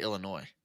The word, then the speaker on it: Illinois: How are you from Nebraska and don't know how to say Illinois? – I don't know. I just said Illinois. – Don't pronounce the S.